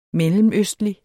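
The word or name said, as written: mellemøstlige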